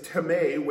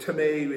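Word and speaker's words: teme (τιμή): And the Greek word is teme, which is spelled time in our English language, but teme is how it's pronounced in the Greek.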